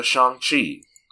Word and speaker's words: Shang-Chi: Let's instead talk about Shang-Chi. And we have to say it that way because that is how it's said in the movie.